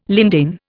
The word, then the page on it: lindane: Indian